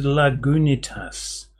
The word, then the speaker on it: Lagunitas: I've heard it pronounced Lagunitas.